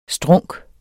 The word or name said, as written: strunk